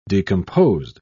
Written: decomposed

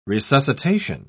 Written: resuscitation

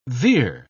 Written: veer

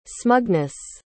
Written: smugness

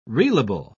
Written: reelable

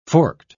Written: forked

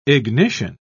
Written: ignition

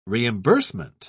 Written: reimbursement

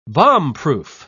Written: bombproof

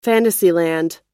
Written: fantasyland

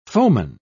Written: foeman

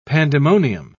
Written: pandemonium

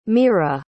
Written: meara

